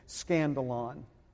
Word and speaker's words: skandalon: It's spelled with a K, but just like it sounds, S-K-A-N-D-A-L-O-N, scandalon.